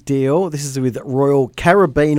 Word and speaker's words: Caribbean: The people from the cruise line tell me it's pronounced Caribbean.